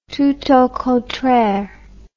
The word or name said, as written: tout au contraire